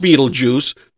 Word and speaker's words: Betelgeuse: It's pronounced Betelgeuse, but this is the way it's spelled in Latin B E T E L G E U S E. Really? Yeah. Now, who's going to say Beetlejuice out of that? Yeah. but that's exact. And so they said, we'll just make it phonetically correct Beetlejuice.